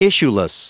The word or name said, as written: issueless